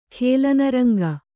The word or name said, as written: ಖೇಲನರಂಗ